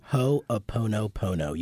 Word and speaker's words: Ho'oponopono: Ho'oponopono.